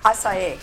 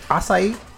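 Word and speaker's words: açaí: How do you pronounce that word? – Acai. – Acai? – Yes.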